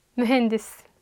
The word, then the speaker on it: mühendis: Mühendis.